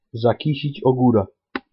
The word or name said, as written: zakisic ogora